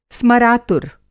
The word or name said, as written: स्मरातुर